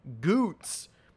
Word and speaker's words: Gutz: We were hanging out with the beer's new mascot, Goots, whose name is spelled Guts.